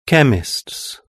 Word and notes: chemist: British English

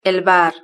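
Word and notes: el bar: Spain

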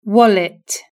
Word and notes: wallet: British English

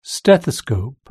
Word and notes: stethoscope: British English

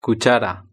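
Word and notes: cuchara: Spanish (Spain)

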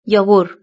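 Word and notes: yogur: European Spanish

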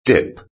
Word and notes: dip: British English

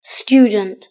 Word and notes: student: British English

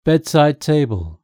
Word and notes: bedside table: British English

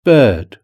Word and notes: bird: British English

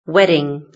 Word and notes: wedding: British English

